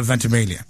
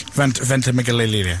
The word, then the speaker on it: Ventimiglia: Now, here's the big question, Milo Ventimiglia. – Yes, Chris. – Ventimiglia. Sorry, I beg your pardon. I didn't say it right.